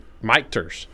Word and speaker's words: Michter's: There's no N in it. – I think it's Michter's.